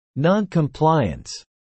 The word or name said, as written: noncompliance